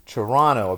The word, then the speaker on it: Toronto: Toronto. Apparently there are no T's in the word Toronto. – There you go. – So for those of you wondering how to pronounce it, now we know.